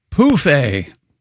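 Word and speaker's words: pouf: Anyway, Europeans of a certain age use the French word pronounced poufé for an ottoman, phonetically spelled P-O-O-F-A, hmm.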